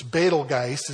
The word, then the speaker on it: Betelgeuse: We want to say Betelgeuse because of the movie, but it's Betelgeuse is the way you pronounce it.